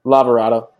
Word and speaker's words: Lavorato: Luke, I always say your name wrong, so I say Lavorato. Am I saying that correctly? How do you say it, Luke? – Lavorato. You nailed it. Perfect. – Nailed it. – Lavorato or Lavorato? – No. V with a V, yeah.